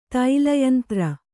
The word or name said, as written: ತೈಲಯಂತ್ರ